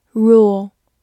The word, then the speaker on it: rule: 字母 u 发长音 u，l 有一个滑音，末尾的 e 不发音。 rule，它是一个动词，也是一个名词，表示规则或者统治。